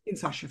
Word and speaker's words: Kinshasa: Kinshasa. Have I pronounced that correctly? – Yeah, the Kinshasa. Yeah, you have. – Kinshasa from behind.